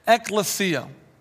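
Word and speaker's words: ekklesia (ἐκκλησία): And whenever you run across the word church in your New Testament, it's a translation of a Greek word that reads like this, ekklesia. Ekklesia, four syllables, okay?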